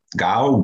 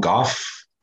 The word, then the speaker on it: Gough: It's spelled G-O-U-G-H. So would that be Gough? Gough? Gough? – Oh, see, I've been saying that.